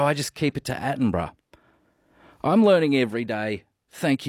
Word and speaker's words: Attenborough: Just a polite reminder, David Attenborough is pronounced as three syllables.